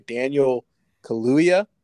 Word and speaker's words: Kaluuya: Daniel Kaluuya is how I've heard it pronounced, and that's what I'm going to go with.